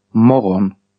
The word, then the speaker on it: morgon: Words with silent letters. G is dropped. – Ja. – I. – Da. – Day. – Dawn. – The day. – Non. – Someone. – Morgon.